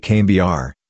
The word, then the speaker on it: Cambuur: Sport Club Cambuur, most often styled SC Cambuur, Dutch pronunciation, SC Cambuur, and sometimes Cambuur Leeuwarden, is a Dutch professional football club in Leeuwarden, the capital of Friesland.